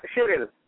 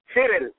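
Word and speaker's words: Cyril: Cheryl. – Cheryl? – Cheryl.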